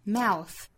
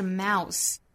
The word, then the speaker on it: mouth: 包括清辅音哈，比如说在单词尾部包含的这个 th，像嘴巴这个词就是 mouth mouth mouth，而不是 mouse，如果发成了 mouse 就是老鼠那个词 mouse 了，所以一定要注意舌头是伸出来的 mouth mouth mouth。